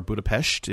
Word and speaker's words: Budapest: This is one of your three co-hosts, coming to you live from Budapest, Hungary, or Budapest, if I pronounce it properly.